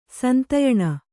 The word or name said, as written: ಸಂಧ್ಯಾನ